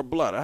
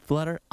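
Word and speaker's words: Bluder: Is it Bluter or Blutter? How do you say this lady's name? – Oh man, I'm pretty sure it's Blutter.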